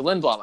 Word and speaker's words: Lindblom: I want to go with Lindblom, which I think is actually the correct way to say it, right? – I think so. – Yeah, I think we've been saying it wrong the last couple years. – Yeah, it's definitely Lindblom.